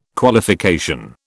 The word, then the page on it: Qualification: British English